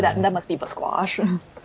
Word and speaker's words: Basquash: Seen some of the show. It's definitely pronounced Basquash.